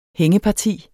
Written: hængeparti

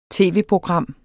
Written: tv-program

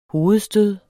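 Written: hovedstød

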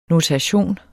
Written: notation

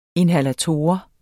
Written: inhalator

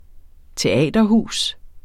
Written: teaterhus

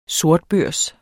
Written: sortbørs

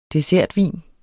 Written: dessertvin

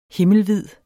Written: himmelvid

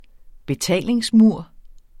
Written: betalingsmur

